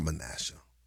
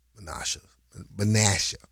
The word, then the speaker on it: Menasha: More than 1,000 homes and other buildings in Menasha were damaged or destroyed. – Menasha. It's not Menasha. Menasha. It's a little Wisconsin thing.